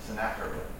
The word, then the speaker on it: Sennacherib: The news that he heard was that Sennacherib, that's how you say it, by the way, Sennacherib. I learned something interesting about Hebrew, is the CHs are just Ks.